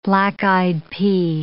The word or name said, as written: black-eyed pea